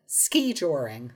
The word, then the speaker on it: skijoring: The word is skijoring. Skijoring. – And that's how it's pronounced? Skijoring? – Yes. Skijoring. Emphasis on the first syllable.